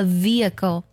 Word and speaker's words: vehicle: 除此之外呢， H 在两个元音中间的时候呢也是不发音的，比如交通工具这个单词读作 Vehicle，它拼作 V-E-H-I-C-L-E Vehicle，交通工具的意思。这里的字母 H 就位于 E 和 I 之间。